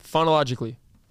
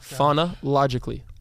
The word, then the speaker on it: phonologically: You're still saying it wrong. Phonologically. – Correct. – Phonologically.